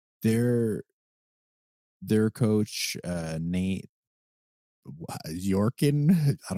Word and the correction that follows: Bjorkgren: Nate Bjorkgren.